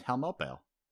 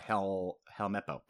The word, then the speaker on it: Helmeppo: Kobe and Helmeppo. Sorry, Helmeppo.